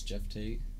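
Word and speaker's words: Geoff Tate: It's spelled like G-off, but it's Jeff Tate.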